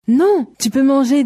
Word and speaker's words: manger: Manger.